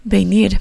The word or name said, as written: venir